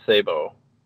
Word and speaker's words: sabot: But then when World War One of these words are French, so I think it's pronounced fléché and sabot.